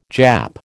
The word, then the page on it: Jap: American English